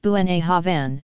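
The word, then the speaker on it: Bunnahabhain: Bunnagh Habhain, pronounced Buenagh Havan, is located in the northern eastern coast of Islay.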